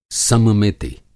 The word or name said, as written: सममिति